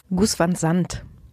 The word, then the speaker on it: Gus Van Sant: Gus Van Sant.